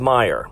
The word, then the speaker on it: Mayer: You might use this stuff every day, but to be honest, you might have thought it was Febreze, B-R-E-E-Z-E, where, in fact, it's only one E. Oscar Mayer is actually Oscar Mayer, M-A-Y-E-R, but still pronounced Meyer. Skechers is S-K-E-C-H-E-R-S, not S-K-E-T-C-H-E-R-S. Fruit Loops is not F-R-U-I-T, it's F-R-O-O-T.